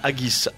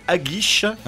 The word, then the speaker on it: haggis: Haggis. – Il y a Gilles qui apparemment. – J'avais l'habitude de le prononcer haggis. Et on m'a dit non, c'est haggis.